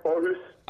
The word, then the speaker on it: Aarhus: You moved to Denmark to play for, I mean, Aras Fremad. I mean, I don't know how to say it. – Yeah, Aarhus.